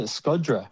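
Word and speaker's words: Shkodra: Yeah, and we went to Skoda, which they actually pronounce Skod. They pronounce it Skodra.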